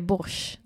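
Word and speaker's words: borsjtj: Men det stavas ju borsch. Det är något te där som inte får vara med i uttalet.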